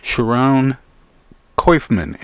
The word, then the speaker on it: Sharon Koifman: Sharon Koifman.